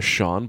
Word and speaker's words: Cyan: And his son's name is Sean, but it's spelled C-Y-A-N. Like the color cyan.